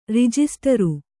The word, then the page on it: ರಿಜಿಸ್ಟರು: rijisṭaru